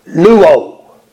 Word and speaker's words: luo: But the word washed in the past tense is the Greek word luo. Luo. That's the way you pronounce it.